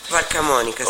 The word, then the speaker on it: Valcamonica: I Luff, che in dialetto credo della Valcamonica, ho detto bene Valcamonica? – Valcamonica, sì.